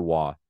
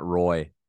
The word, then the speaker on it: Roy: Is he Roy or Wah? – Matt Roy. Yeah.